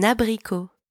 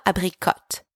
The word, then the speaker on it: abricot: Un abricot. – An apricot. – Un abricot. – Hey, quick pronunciation note here. It's not abricot. The T is silent. Pretend it's not even there. Abricot.